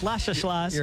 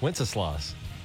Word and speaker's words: Wenceslas: Lashaslas. – You're an idiot. You don't even know the name of the song. – From Good King Wenceslas. How do you say it? – Wenceslas.